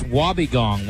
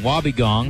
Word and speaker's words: wobbegong: Pronounced wobbegong, wobbegong.